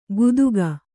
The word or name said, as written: ಕುತುಕ